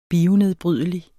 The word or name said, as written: bionedbrydelig